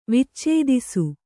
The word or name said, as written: ವಿಚ್ಚೇದಿಸು